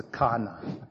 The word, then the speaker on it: qana: Q-A-N-A. How, if I was Zulu, how would I pronounce this name? Because in English we realize what comes after any Q, it's not the same. So it's basically just, it pronounced as K, as a Kana.